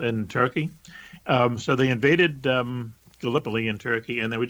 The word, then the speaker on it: Gallipoli: And what it was is it's actually, I think, pronounced Gallipoli. It's a place in Turkey.